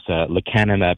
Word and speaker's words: lecanemab: I'm probably going to mess up the pronunciation, but the drug is lecanemab? – Lecanemab, yeah.